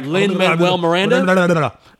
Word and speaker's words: Lin-Manuel Miranda: Every time I say it, like Lin-Manuel Miranda, I was like Lin-Manuel Miranda.